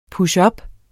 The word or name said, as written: pushup